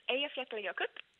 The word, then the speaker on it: Eyjafjallajökull: So it's Eyjafjallajökull.